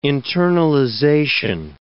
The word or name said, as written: internalization